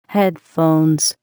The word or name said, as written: headphones